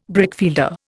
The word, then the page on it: brickfielder: Indian